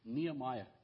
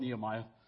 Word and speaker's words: Nehemiah: As I understand it from those who study Hebrew, his name is more probably pronounced Nehemiah, because of how the Hebrew language is, but it's so inculcated in my mind as Nehemiah.